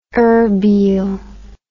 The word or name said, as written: irbil